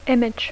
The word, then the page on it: image: English